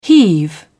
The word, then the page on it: heave: British English